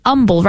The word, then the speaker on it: Humble: Goes to St. Mary Magdalene, and it's an humble, right? Humble. There's no H in humble, right? – Well, there is an H, but it is silent. – It is silent. It's not there audibly.